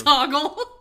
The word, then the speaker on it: Hoggle: It's Hoggle. – Okay. – You got it right. Like Boggle, but with an H.